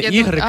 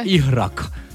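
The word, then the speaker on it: ігрек: Вчителька з алгебри, бо вона саме так вимовляла це слово. Не ігрик, а іграк.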